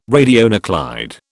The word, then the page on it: radionuclide: British English